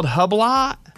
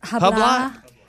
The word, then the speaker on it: Hublot: It's called Hublot. – Hublot. – I don't know. It's spelled L-A-A-T whenever you learn how to say it.